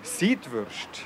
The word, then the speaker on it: Seidwurst: Das ist also eine Seidwürst. Habe ich das richtig gesagt? Ich frage den Ruhr in Zeller, er ist Metzgermeister aus Herisau. – Ja, das ist korrekt. – Seidwurst.